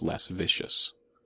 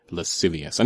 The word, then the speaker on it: lascivious: Again, not pronounced, less vicious. Say it with me, lascivious.